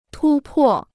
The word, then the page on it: 突破: tū pò